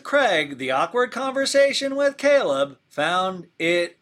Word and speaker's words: Chleb: You say it, ah, you say it Caleb, but it's spelled C H L E B, but in my head, it's Chubbs.